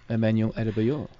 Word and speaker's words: Emmanuel Adebayor: From how do you pronounce that, mate? – Emmanuel Adebayor. – That's better.